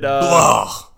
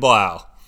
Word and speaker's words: Blau: And with David, how do you say his name? David Blue? David Blah. – David Blah. – David, yeah. That speaks for itself. David Blau. – Yes, thank you. David Blau.